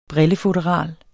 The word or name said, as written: brillefutteral